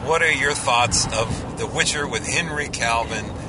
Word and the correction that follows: Cavill: Well, first of all, it's Henry Cavill. Okay. Just want to say his name correctly because, you know, it's just only fitting that we do Henry that.